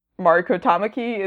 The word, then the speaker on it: Mariko Tamaki: Mariko Tamaki, who we talked about a little bit last week, or Mariko Tamaki is maybe the more correct pronunciation, is writing the new X-23 series, I think, next month, Ooh.